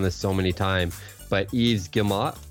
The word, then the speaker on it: Yves Guillemot: It's Yves Guillemot.